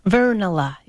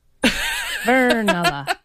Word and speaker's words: Vernala: Did I get the name right? – Vernala. You got to be a little more Finnish on that. – Vernala. – It's like a brick falling downstairs. Vernala.